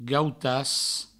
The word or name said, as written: gautas